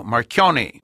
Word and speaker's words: Marchionne: Per esempio Sergio Marchionne, che è riuscito a trasformare la Chrysler e che in America lo chiamano Marchionne con una pronuncia con la I finale.